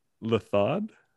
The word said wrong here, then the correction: Raftaar: Raftad, like R. – Raftad. – Ruff, like rough. – Okay. – Raftad. Yeah, there you go.